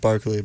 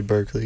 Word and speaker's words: Berkeley: Technically pronounced Barkley, but I say Berkeley because we're Americans.